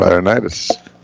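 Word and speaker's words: Laurinaitis: I never can say his name right. Laronitis. – There we go. Laronitis.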